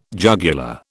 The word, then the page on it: jugular: British English